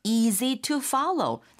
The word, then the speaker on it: easy to follow: 실제 발음할 때 to는 약하고 낮게 빠르게 right. – easy to follow가 되겠습니다.